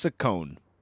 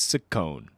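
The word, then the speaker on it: Ciccone: Apparently it's Saccone. – Didn't know that. – Yeah, I didn't either. Madonna Louise Saccone. One of her fans is really upset that I'm pronouncing it wrong. It's C-I-C-C-O-N-E, but I'm going to pronounce it Saccone.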